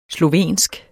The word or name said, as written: slovensk